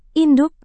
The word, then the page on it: induk: indonesian